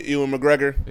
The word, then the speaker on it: Ewan McGregor: Ewan McGregor? – Yeah.